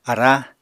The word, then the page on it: ará: Apatani